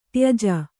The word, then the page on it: ತ್ಯಜ: Kannada